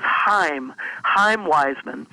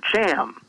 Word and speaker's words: Chaim Weizmann: The actual pronunciation is Chaim Weizmann, but Harry Truman, President Truman, called him Cham, and Weizmann never corrected him.